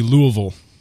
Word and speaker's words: Louisville: I guess I'm saying that wrong. It should be Louisville.